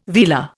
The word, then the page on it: velar: Indian